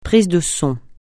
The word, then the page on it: prise de son: francais